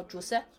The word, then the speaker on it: Jose: 那个是那个人叫 Jose，就是 Jesus 那个词的西班牙发音。